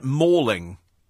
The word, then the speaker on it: Malling: Malling is pronounced like mauling, says Rob from Tooting. Oh, so it's mauling.